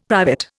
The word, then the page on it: private: Indian